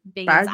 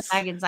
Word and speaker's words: Bagans: He pronounces his name Bagans. I used to call it Bagans.